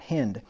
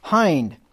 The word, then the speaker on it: hind: Somebody just told me the other day that I've been pronouncing that wrong for my entire life, and she was right. "Let her be as the loving hind," not hind, "hind, and pleasant roe.